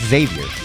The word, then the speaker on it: Xavier: It's pronounced Xavier. – Xavier. – The X sounds like a Z. It's not Xavier.